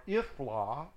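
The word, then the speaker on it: Ithlah: Yithla. Or Ithla. The Y is silent mostly. 3494. Verse 43, Elon, Timnah, Ekron, E-Q-R-O-N,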